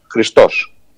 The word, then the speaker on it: Χριστός: Ο Ιησούς Χριστός, που είναι ο Θεός μας, η προσωποποίηση του καλού, τονίζεται στη λίγουσα, Χριστός. – Ναι. – Όποιος όμως έχει το όνομά του, ονομάζεται Χρήστος, επειδή δεν μπορεί να ταυτιστεί με το απόλυτο καλό και να διαχωριστεί από αυτόν τον μοναδικό, τον ένα, τον Ιησού Χριστό.